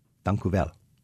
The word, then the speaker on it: Dank u wel: Dank u wel. – Alsjeblieft. – Did I say that right? – Yeah, you said perfect.